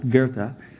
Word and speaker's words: Goethe: And, you know, the interesting thing is the German philosopher looks like on paper Goethe, but it's actually pronounced Goethe.